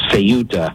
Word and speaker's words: Ceuta: So I was like, well, Ceuta. And the pronunciator online told me it's Ceuta.